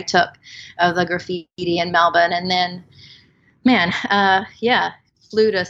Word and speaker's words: Melbourne: And that's how they say it too. It's not Melbourne. – I was about to comment. Thank you for pronouncing it correctly. Anyway, Melbourne.